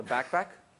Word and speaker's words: BACPAC: Microsoft have now got something called a Backpack. That's a funny name. It's you know, it's spelled B-A-C-P-A-C, and you can use backpacks to also migrate your schema as well as your data across to the cloud.